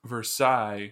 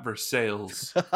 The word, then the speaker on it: Versailles: It's Versailles, not Versailles.